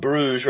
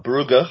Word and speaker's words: Brugge: And there were just a couple of changes from the game against Club Bruges or Club Brugge, however you like to pronounce it, and that was that Gundogan and Gabby J were back in, and De Bruyne and Riyad Mahrez dropped to the bench.